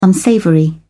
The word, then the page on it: unsavoury: British English